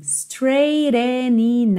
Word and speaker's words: straightening: E essa frase final, olha, we've got some straightening out to do, straightening out to do. Porque, ó, straightening, o GH é mudo, então você tem straight, o GH é mudo.